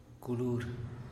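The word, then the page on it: culur: Teotitlán Zapotec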